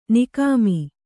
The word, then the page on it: ನಿಕಾಮ: Kannada